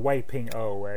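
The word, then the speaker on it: Wei Ping-ao: Like, you have the unscrupulous developer, and you have his sniveling little toady assistant, who's Ngai Ping. I'm not sure how to pronounce that actor's name. – Wei Ping O. You've actually seen him probably in Fist of Fury. He plays that slimy translator for the Japanese. That's Wei Ping O.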